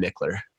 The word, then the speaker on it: Michler: And then I'm pretty active on Instagram, and Twitter, and that's at Ryan Mickler. My last name is spelled M-I-C-H-L-E-R. So it's at Ryan Mickler.